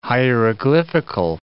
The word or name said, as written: hieroglyphical